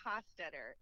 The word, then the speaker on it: Hostetter: It's LeeMarie Hostetter. It's Hostetter. – German.